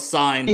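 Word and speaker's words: Cine: Is it Sini or Sine? – Yeah, we've just been going with Sine. – Yes, like Sine.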